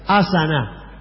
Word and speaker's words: asana: Asana. – Asana. – Asana, A-S-A-N-A. Como en Aná, pero con S y con acento en la primera A.